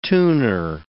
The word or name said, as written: tuner